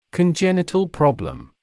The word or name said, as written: congenital problem